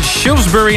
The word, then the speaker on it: Shrewsbury: Hij komt uit Engeland en is geboren in Shrewsbury in Engeland.